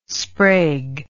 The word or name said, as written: Sprague